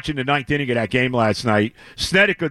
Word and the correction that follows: Snitker: decides to Snitker, however you say his name, the manager for the Braves. – Snitker, yes.